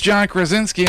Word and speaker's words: Krasinski: No, I would think Krasinski. Is that how you say it? Krasinski? – Yeah, that's let's awesome. Go with Angela. – You're right. It is John Krasinski, and it happens to be John Krasinski's birthday.